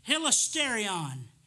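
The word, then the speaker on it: hilasterion (ἱλαστήριον): Propitiation in the Greek is pronounced, hilasterion.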